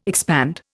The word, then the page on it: expand: Indian